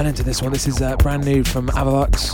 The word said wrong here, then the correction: Alavux: Alavux even. Man, pronunciation some of these names I should know.